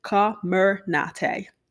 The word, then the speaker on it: Comirnaty: on how you pronounce it, Comirnaty vaccine.